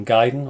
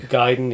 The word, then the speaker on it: Gaiden: I bought myself Double Dragon Gaiden, or Gaiden, or however you want to call it. – You said it right first time, I think. – Gaiden, yeah.